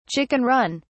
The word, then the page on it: chicken run: British English